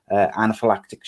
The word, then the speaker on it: anaphylactic: We also spoke to current Warsaw captain James Clark about his issues with PTSD following anaphylactic shock.